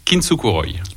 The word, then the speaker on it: Kintsukuroi: Est-ce que j'ai bien prononcé le titre de ton EP? – C'est ça, tout à fait. Ça se prononce Kintsu Kuroi.